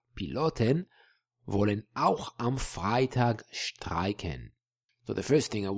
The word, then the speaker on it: streiken: Sounds very similar in German, streiken, but it shows us that whenever we've got an S in the beginning of a word in German, it is pronounced like sh.